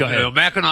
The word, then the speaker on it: Mackinac: When he goes to Mackinac Island? – Oh, no, Mackinac Island. – Mackinac Island. – First of all, yeah. – It's spelled, why do they spell it Mackinac when it's Mackinac?